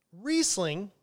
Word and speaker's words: Riesling: Riesling is how it's pronounced, like Reese's Peanut Butter Cup, Riesling. It comes from Germany,